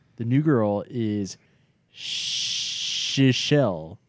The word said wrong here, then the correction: Chrishell: Chrishella. – Chrishell. – Chrishell. – Like Chris with a shell.